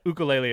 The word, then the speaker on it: ukulele: I get all the pronunciations wrong about everything, but ukulele is correct, I promise.